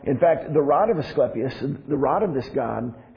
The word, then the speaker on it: Asclepius: They had a hospital of Asclepius. I'm sorry. I want to say this right. Asclepius.